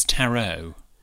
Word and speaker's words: tarot: A tarot card? It's tarot. – Oh, is it? – Yeah. The T is silent on the end.